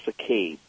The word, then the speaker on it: saccade: So today we're going to talk about a more high-level eye movement, and this is called the saccades. And saccades, it's kind of an unusual word, and it is spelled S-A-C-C-A-D-E, saccade.